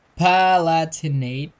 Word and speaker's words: Palatinate: Bopard Rhineland-Palatine, if I'm saying that right. No, Palatinate, I guess is how you pronounce it.